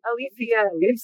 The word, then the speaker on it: Alycea: Alycea.